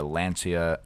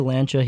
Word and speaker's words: Lancia: If our listeners want something cool to look up, just go and look up the Lancia or Lancia. – No, I say Lancia. He says Lancia, but it is the C is more of like a CH.